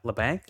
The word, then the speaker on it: Labanc: I mean, okay, so if they give up Meier, they give up say LeBlanc, LeBlanc, Labanc, Labanc. I always say his name wrong.